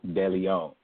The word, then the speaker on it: Deleon: It is Scanlouz, but the last name is pronounced Deleon.